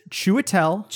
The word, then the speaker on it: Chiwetel: It's Chiwetel.